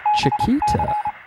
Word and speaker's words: Chiquita: All right, our first email comes from Chiquita. I like that name, by the way. – It's like the bananas. – She actually told me how to pronounce it. She said it's like Chiquita the Bananas.